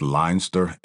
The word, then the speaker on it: Leinster: But I'm pronouncing this Leinster, L-E-I-N-S-T-E-R.